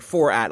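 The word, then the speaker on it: Fourattic: As if you took the word for, like F-O-U-R, and then attic. – Like four attic.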